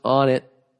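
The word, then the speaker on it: Onnit: I know you're buddy with Joe Rogan, and I'm not sure if he's already turned you onto this, but his company On It, pronounced On It.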